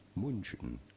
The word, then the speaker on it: München: pronounced as Bayern München. München. Bayern München.